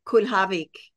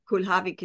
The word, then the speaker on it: Kulhawik: It's actually Kulhawik. Kulhawik is the real Polish pronunciation, yes. – But we anglicized it.